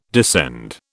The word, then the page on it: descend: British English